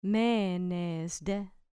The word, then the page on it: menes de: grec